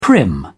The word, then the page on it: prim: British English